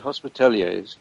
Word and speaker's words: Hospitallers: how do you pronounce that? Hospitallers? – Hospitaliers, yes.